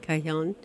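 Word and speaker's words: cajón: How do you say it? Cajon? C-A-J-O-N. Cajon, I think.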